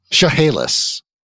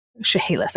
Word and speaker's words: Chehalis: so you guys can tell me that how to pronounce that tribe's name, because I'm going to say it wrong. – Chehalis. – Chehalis. Okay. Yes.